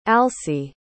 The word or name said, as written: alcie